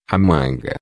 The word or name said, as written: manga